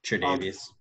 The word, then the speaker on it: Tre'Davious: Tre'Davious.